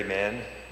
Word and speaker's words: Mann: Gary Mann, G-A-R-Y-M-A-N-N.